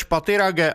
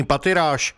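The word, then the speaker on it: Pathirage: Vyslovoval své jméno Rumesh Pattirage a vyloučil anglickou výslovnost svého příjmení Pattiráž.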